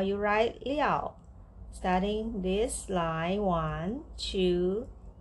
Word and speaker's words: 料: liao.